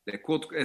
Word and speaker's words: LeCoultre: Oye, a ver, el quinto reloj más caro del mundo es, nunca he sabido cómo se pronuncia, Jaeger LeCoultre. – LeCoultre, exactamente, es bastante complicado de pronunciar